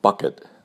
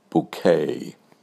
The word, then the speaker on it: Bucket: You may have seen the British comedy on PBS, Keeping Up Appearances, about Hyacinth Bucket. She insists pronouncing it bouquet.